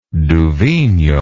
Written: duvigneaud

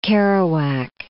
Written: Kerouac

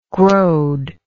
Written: growed

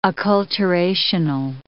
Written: acculturational

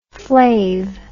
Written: flav

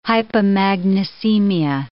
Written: hypomagnesemia

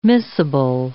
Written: miscible

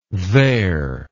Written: theyre

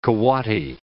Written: coati

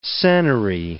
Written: senary

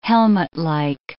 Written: helmetlike

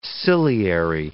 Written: ciliary

